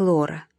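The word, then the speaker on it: Laura: По-английски «Лаура» произносится как «Лора», и таким образом полностью рифмуется с Флорой.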